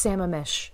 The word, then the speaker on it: Sammamish: 1992, the police get a call that there's been a drowning on Lake Sammamish. I got to be saying that wrong. – Sure. It's Sammamish. They'll forgive you.